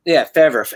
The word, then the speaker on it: Fiverr: Fiverr. It's pronounced Fiverr because it's technically like $5 on it. So Fiverr.